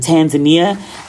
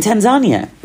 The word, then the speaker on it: Tanzania: That and I think I said I was in Tanzania, and she was like, in Tanzania.